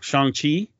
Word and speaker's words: Shang-Chi: I still, maybe they didn't launch anything on that day other than, I'm going to say this wrong, Shang-Chi, right? – Yeah.